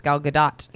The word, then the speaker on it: Gal Gadot: I always, but then unlike the way they said it on the show, is Gal Gadot.